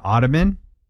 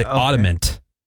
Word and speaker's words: ottoman: An ottoman? – An ottoman. All right. Yeah. People say ottomant. – Oh, ottoman? Really? Mint? – Yeah, that's the most common way that people butcher that one.